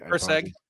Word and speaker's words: Erceg: Do we learn anything about Erceg, if I'm saying it correctly? – Erceg. Yeah, Erceg. Erceg. Yeah.